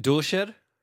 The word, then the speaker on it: Doescher: Is it Doscher? It's even hard to pronounce in German. – I believe it's Doscher.